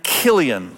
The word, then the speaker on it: Chilion: The story of Ruth is about this couple, Elimelech and Naomi, who are living in Bethlehem, and they have two sons, Mahlon and Chilion. That's how you pronounce them.